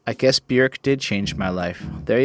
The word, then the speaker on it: Björk: just by the way, her name is pronounced more like Björk, and she rhymes with jerk.